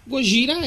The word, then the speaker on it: Gojira: E, e, tipo assim, a gente fez aquela brincadeirinha que, ah, Gojira é de Godzilla, porque assim, Gojira é a pronúncia de Godzilla em, japonês.